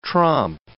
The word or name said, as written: tromp